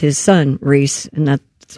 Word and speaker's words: Rhys: He pronounces it Reese. – Reese. – Does he? I don't know. – Oh, it is Reese.